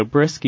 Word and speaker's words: bris: Not like brisk, like tea. – What? Spell it. – B-R-I-S? There's no K at the end. – Can someone Google this? I'm challenging. – A brisk is, there's no K. – It is bris, no K.